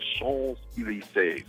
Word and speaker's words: Champs-Élysées: And you said it kind of, you said it right, man, because, you know, a lot of times people say Champs-Élysées. And one of my French friends, you know, Alex Bougnon. – Sure. – So he pulled me aside, he's like, look, man, I got to teach you how to say this. He says, the Champs-Élysées.